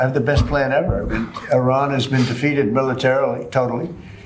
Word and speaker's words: Iran: And that's also important, you know, having to do with the absolutely lovely country of Iran. That's the way they pronounce it.